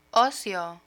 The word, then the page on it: ocio: Spanish